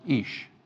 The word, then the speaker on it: Ish: The word here is Ish, pronounced Ish.